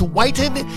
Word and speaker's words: Whitten: It's spelled W-H-I-T-T-E-N, but it's Whiten, and you can bet on the fact that that's the right spelling.